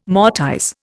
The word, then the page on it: mortise: Indian